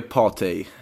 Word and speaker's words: Partey: Eh, du nämnde ju Partey här. Eh, Uttalade det rätt brittiskt eh, i alla fall.